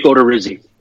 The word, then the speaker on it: Odorizzi: And then game three, Trevor Cahill versus, how did you say his name? Odorizzi? Jake Odorizzi. Odorizzi, okay. I apologize for that.